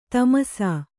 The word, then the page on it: ತಮಸಾ: tamasā